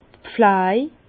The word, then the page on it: d' Pfläj: Bas-Rhin; Herrlisheim